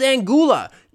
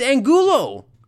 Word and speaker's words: Angulo: It's like, who is Angula? You look at the TV screen, and they've got this little caption that shows the names of the guys. It's Angulo. Do you not know how to pronounce basic vowels?